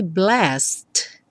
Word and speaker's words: blessed: Esse blessed fica tão reduzido porque o E não é pronunciado. Então, seria blessed.